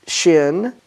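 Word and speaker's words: Shin (שׁ): And when the dot's on the right side, then we pronounce it as Shin. And if it's on the left side, we pronounce it as Sin or Sin.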